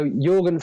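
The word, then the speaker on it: Jørgen: So Jorgen. – Jorgen, wow. I've been saying it wrong for like 4 years now. – Yeah, he's Norwegian. It's like a Y over there.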